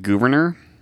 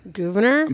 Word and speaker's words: Gouverneur: Yeah, last week I pronounced it Gouverneur. – Gouverneur? – But historians are pretty sure it's Gouverneur.